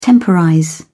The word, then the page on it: temporize: British English